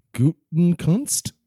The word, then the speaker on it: Gutekunst: Yeah, even the Packers GM, Brian Gutekunst.